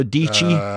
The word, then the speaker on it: Deechy: But the one that I am totally in love with is this young woman named Sarah Deechy, rhymes with peachy.